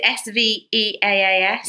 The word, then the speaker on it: Sveaas: It's a T-bar gold necklace, and I never know how to say this lovely designer's name. Tilly Sveas. – That's how I've always said it. – Is it Sveaas? – Yes.